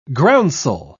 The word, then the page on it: groundsel: British English